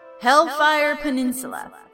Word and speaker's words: Hellfire Peninsula: I'm sorry, I said that wrong. Hellfire Peninsula. That should always be said that way.